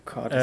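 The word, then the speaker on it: Cádiz: Ja, genau, Cardis.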